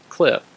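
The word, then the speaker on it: Clif: You'll also see right after my name is my Twitter handle, which is at Paul Allen Cliff.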